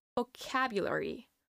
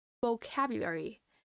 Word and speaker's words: vocabulary: Next up, we have another word, and that is vocabulary. Vocabulary. The stress is in the ah sound, as in cat.